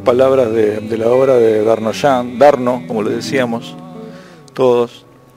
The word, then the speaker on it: Darnauchans: Eduardo Darnoyan, apellido, se escribe Darnauchans, Darnoyan.